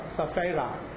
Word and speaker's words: Sapphira: There is this case of Ananias and Sapphira. Did I pronounce it right? Sapphira. Right.